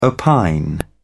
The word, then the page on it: opine: British English